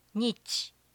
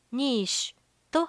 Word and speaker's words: niche: ニーシュマーケットと発音するのかクリスさんに聞いてみました建築や生物学的な意味の時はニッチと発音するけどマーケティングに関してはニーシュと発音している